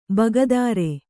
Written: ಭಾಗೇದಾರ

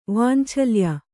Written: ವಾಂಛಲ್ಯ

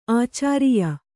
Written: ಆಚಾರಿಯ

